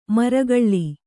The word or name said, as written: ಮರಗಳ್ಳಿ